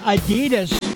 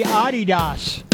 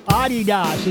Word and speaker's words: Adidas: In addition to the orange juice, something else we do here in the good old USA is pronounce Adidas wrong. It's supposed to be Adidas. Adidas is the correct pronunciation of those sneakers that soccer players wear.